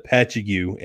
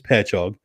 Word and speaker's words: Patchogue: When I first got to Long Island, I was calling it Patchogue, and I was chastised for that. I believe the correct enunciation is Patchogue.